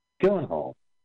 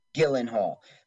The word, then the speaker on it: Gyllenhaal: Gyllenhaal? What is it? – Jake, right? Or Maggie? – Yeah, what's the last name? – Gyllenhaal. – Gyllenhaal.